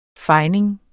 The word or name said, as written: fejning